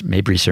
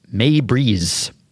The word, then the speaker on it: Meibrys: Uh, by the way, I was wondering how you pronounce Meibrys. I, I assumed it was Mabris or Mabris. The baseball baseball reference pronunciation says May Breeze.